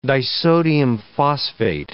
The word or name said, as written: disodium phosphate